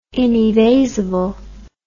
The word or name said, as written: inevasible